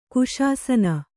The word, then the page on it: ಕುಶಾಸನ: kuśasana